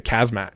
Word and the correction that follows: Khamzat: Khamzats, this is his third fight in, I think, 66 days.